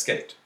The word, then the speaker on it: Sk8: S-K-8. Or okay. – Pronounced as Skate.